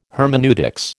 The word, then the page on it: hermeneutics: American English